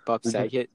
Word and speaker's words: Bob Saget: Bob Seger? What is that? How do you say his name? – Bob Saget.